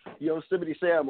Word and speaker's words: Yosemite Sam: Yosemite Sam, or Roadrunner, or something like that.